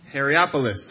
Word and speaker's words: Hierapolis: How do you say that? Hierapolis. Thank you.